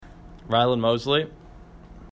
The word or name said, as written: Mosley